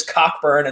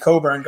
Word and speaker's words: Cockburn: Hasn't had a dominant season as much as I thought, and also maybe it's because he changed the pronunciation of his name. I'm pretty sure he was Cockburn until this year, and now he's going by just Kofi Coburn.